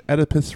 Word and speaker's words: Oedipus: Oedipus Rex.